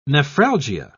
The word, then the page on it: nephralgia: British English